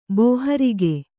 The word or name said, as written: ಬಾಹುರಕ್ಕೆ